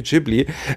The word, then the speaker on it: Ghibli: Ja, aber hat Studio, wie wir Filmkenner es aussprechen, Studio Ghibli.